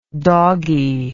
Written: dogginess

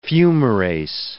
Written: fumarase

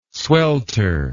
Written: swelter

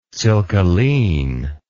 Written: silkaline

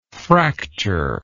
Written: fractured

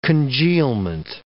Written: congealment

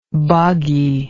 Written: bogginess

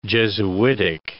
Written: jesuitic

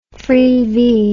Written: freevee

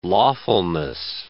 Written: lawfulness

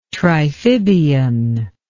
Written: triphibian

